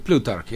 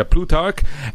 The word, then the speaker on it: Plutarch: Am I pronouncing that right? – Plutarch, yeah. – Yeah, Plutarch.